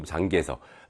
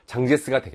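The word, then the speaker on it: 장제스 (蔣介石): 우리말로 이제 발음으로 장개석. 대만 쪽에서는 장카이세. 그 다음에 이제 흔히 장제스. 중국 발음 그대로 살려서 장제스가 되겠고, 그 다음에 이제 요런 표현들 같이 나올 수 있겠다.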